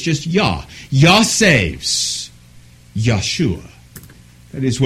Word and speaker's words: Yeshua: Very few languages have that same soft J sound that we have in English. So very few actually would pronounce it Joshua, but it's going to be Yahshua, or perhaps you have heard Yeshua.